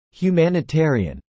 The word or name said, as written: humanitarian